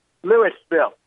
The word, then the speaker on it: Louisville: And by the way, you're pronouncing it incorrectly, Chris. One of the coaches have to do is teach people when they're doing something wrong, teach them the correct way to say it. Louisville. It's no silent S. There are no silent S's in my world.